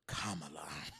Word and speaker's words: Kamala: So you can remind me if you want to, but the only thing I came to talk about today was, as the commander-in-chief said, pronounce it, Kamala.